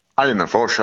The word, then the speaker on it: Annerfors: Är det Annerfors man uttalar ditt efternamn? – Annerfors, ja, det stämmer bra.